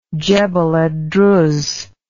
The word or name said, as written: jebel ed druz